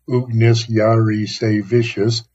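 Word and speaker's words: Ugnius Jarusevicius: A first-team All-MAC pick in 24-25 at Central Michigan, Juruskevicius, okay, it's pronounced Ugnius Jaruskevicius,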